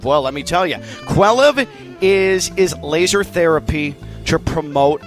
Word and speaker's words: Quelliv: And that's where Quelliv comes in. Let me spell it for you. Q-U-E-L-L-I-V. – One more time. – Q-U-E-L-L-I-V.